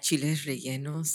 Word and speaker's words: chiles rellenos: Oh, and her chile rellenos. How do you say it? – Chiles rellenos.